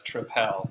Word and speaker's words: Tripel: I've always heard it as Tripel because it's Belgian. It's right, because we want to say something different than the word triple, right? – Exactly. – Because it's not spelled like triple. It's T-R-I-P-E-L, so Tripel.